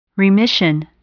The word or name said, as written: remission